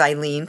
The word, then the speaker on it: Ilene: So yes, I am Eileen the Lice Queen. Fortunately, my name is Eileen because it rhymes with queen.